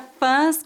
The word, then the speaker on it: fãs: A palavra fãs, com som nasal, é o plural da palavra fã, que é uma pessoa que admira alguém ou algo. A pronúncia dessa palavra é feita com o A nasal.